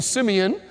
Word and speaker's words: Simeon: Simon, the Hebrew pronunciation, Simeon, means unstable, vacillating as water, and he was just called a stone.